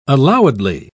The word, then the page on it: allowedly: British English